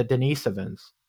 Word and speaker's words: Denisovans: Have you heard of the Denisovans? – Okay, I talk about that in my book. The, the, Denis, Denis, uh, I can't pronounce it. Denisovans. There I go, got it out.